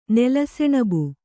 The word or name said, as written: ನೆಲಸೆಣಬು